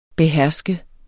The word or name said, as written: beherske